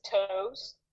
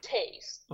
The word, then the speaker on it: Toews: People think that it's spelled T-O-E-W-S, right? So people think that his name is Toes because he has white feet. – Okay. – But his name is Tase.